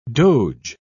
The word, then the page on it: doge: British English